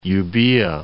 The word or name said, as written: Euboea